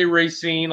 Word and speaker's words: Racine: Am I saying it correctly? Racine, is that where you grew up? – I say Racine. A lot of people from Racine say Racine, but it's a lot of people around the state say Racine, either one.